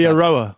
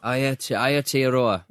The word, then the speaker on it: Aotearoa: Aotearoa. – Aotearoa.